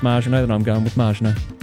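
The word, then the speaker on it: Maginot: To those smart Alex who had the arrogance and ignorance to criticise your pronunciation of Marginot, they're utterly wrong and you are correct. The A in French is pronounced as we pronounce the indefinite article. In other words, as you pronounced it, there is no short A as an apple in French pronunciation.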